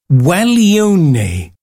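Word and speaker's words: Guaglione: I've had to look up the pronunciation of this, and I do believe it's Gualeone by Perez Prado,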